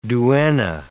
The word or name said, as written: duenna